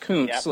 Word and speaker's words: Kuncz: My last name is... last name is Kuncz, and it kind of sounds like a body part if you look at it. It's pronounced Kuntz, like the author.